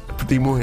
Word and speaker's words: Podimo: Jeg ved ikke, hvordan det udtales. Jeg siger Podimo. Pa- Podimo. Okay, Podimo. For jeg er okay podcast. Jeg er okay Podimo. Jeg synes bare, det lyder... Podemo? Podemo, ja. P-O-D-I-M-O. – Det har jeg altid sagt, Podemo.